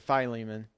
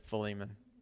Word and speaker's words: Philemon: In the book of Philemon, I've heard some people pronounce it Philemon. I've always said Philemon.